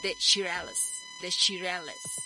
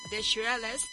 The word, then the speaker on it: The Shirelles: De, déjeme que lo pronuncie perfectamente bien, The Shirelles, así se llama, The Shirelles, Will You Love Me Tomorrow.